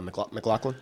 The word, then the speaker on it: McLaughlin: no, people people are praising a number of the performances, particularly uh, Caleb uh, McLaughlin.